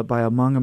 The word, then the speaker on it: Hmong: And I told her, "Well, it's a book of poems by a Hmong American poet," and she goes, "What is Hmong?"